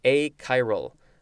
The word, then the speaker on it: achiral: I don't even remember how I pronounced it before, but achiral.